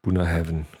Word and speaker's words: Bunnahabhain: Der Chatkraft war, wie man das schreibt, B-U-N-N-A-H-A-B-H-A-I-N. Ich schreibe es gerade nochmal in den Chat rein. – Buna Hab Hein. – Ja. Buna Heaven gesprochen.